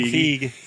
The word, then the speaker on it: Feige: Kevin Feige. – He came out and said... – Feige? – It's like beige, so it's beige.